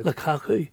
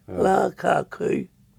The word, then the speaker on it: Lukaku: Lukaku, you put in the three syllables, Lukaku, and it's Lakaku, Lukaku. – Aye. – So you just say what you see.